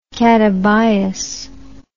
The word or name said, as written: catabias